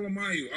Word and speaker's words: Alemayehu: That's how I'm pronouncing it, right? Well, Alemayehu, Alemayehu, Alemayehu, Alemayehu.